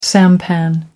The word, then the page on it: sampan: American English